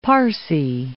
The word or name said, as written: Parsee